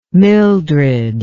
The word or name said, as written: mildred